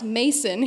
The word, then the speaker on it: Macon: And I was, it's supposed to sound like bacon. And I said, oh, no, let me introduce you to a few country songs off the internet. It's pronounced Mason here.